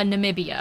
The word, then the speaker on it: Namibia: Um, so we have Adriana Lima, from Brazil, Alessandra Ambrosio from Brazil, Behati Prinsloo from, how do you say that? Nambia? – Uh, Namibia.